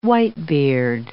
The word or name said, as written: whitebeard